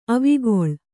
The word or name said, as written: ಅವಿಕಲ